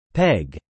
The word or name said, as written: peg